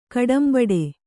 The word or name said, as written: ಕಡುಂಬುಟ್ಟು